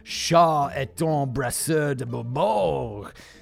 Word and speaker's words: Charles-Antoine Brasseur de Bourbourg: Charles-Antoine Brasseur de Bourbourg.